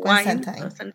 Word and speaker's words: Constantine: Constantine, como, sí, sí, como wine, como Constantine. – wine. – Rhymes with wine.